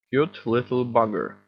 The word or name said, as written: cute little bugger